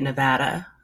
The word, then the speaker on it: Nevada: And thank you, by the way, for saying it, Nevada.